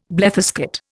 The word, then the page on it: bletherskate: Indian